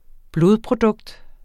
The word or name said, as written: blodprodukt